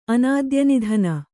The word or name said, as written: ಅನಾದ್ಯನಿಧನ